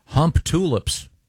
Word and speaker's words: Humptulips: In Grays Harbor County, Washington, there is a town called Hump Tulips. I wonder if they pronounce, maybe it's pronounced Hump Tulips. – Boy, Hump-de-lips is almost more offensive. – Yeah. – I like it. Yeah. But yeah, it's Humptulips.